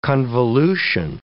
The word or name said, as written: convolution